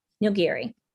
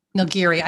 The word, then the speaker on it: Nilgiri: So what makes, I really love Assam, and is it pronounced Nilgiri? – Nilgiri. – Nilgiri. I've said it wrong all this time.